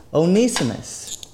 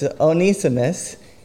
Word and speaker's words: Onesimus: His name is Onesimus. And again, it's not Onesimus, it's Onesimus, even though it's spelt with one these Greek names, Onesimus.